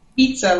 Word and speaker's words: Vizza: Pizza, like pizza.